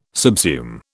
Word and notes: subsume: British English

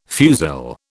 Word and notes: fusil: British English

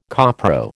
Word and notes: copro: American English

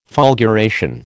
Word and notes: fulguration: American English

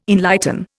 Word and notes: enlighten: Indian

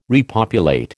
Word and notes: repopulate: American English

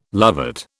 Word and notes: lovat: British English

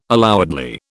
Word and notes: allowedly: British English

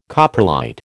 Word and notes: coprolite: American English

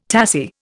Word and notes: tassie: Indian

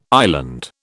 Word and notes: island: British English

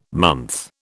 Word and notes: month: British English

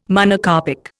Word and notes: Monocarpic: Indian

